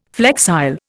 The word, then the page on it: flexile: Indian